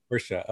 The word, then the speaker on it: Friscia: Am I saying that right, Matt? – That is totally correct. Friscia. Yep. – Friscia. Okay.